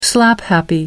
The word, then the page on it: slaphappy: American English